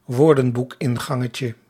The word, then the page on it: woordenboekingangetje: Dutch